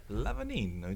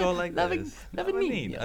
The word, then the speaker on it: Lavagnino: Lavagnino, Italiano. You gotta Lavagnino.